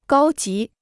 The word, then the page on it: 高级: gāo jí